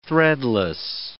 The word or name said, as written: threadless